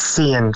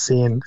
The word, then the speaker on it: Sian: It's Sian for short. Son Vizé's his longer name. It's Sian. Sian is for short.